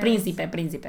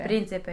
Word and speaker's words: príncipe: How do you say prince? – Príncipe, príncipe. – Príncipe.